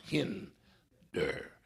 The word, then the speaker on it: hinder: The emphasis is on the first syllable, and it's a short I, so it makes the E sound, hinder. So it was, he was in the hinder part of the ship, asleep on a pillow.